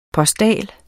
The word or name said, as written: postal